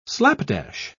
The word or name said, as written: slapdash